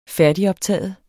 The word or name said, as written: færdigoptaget